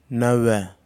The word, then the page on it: nawha: Kwamera